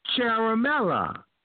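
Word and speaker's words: Ciaramella: So now, since he's been exposed all throughout alternative media, we now know the way you pronounce his name is Charamella.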